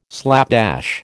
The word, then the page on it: slapdash: American English